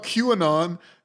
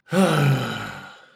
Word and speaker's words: QAnon: I tried to make it into Q. 2 syllables instead of 3. I'm sorry. – QAnon. – QAnon. Just 2 syllables.